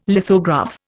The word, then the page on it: lithograph: Indian